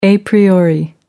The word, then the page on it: a priori: American English